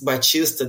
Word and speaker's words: Baptista: And I always want to say, "My name is Batista. The P is silent."